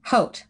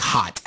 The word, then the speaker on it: haute: Hey, wait, is that how it's pronounced? – Haute, like haute cuisine. – I've always just said like hot.